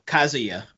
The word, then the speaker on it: Kazuya: Kazuya.